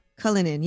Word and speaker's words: Cullinan: Cullinan. Cullinan, yes.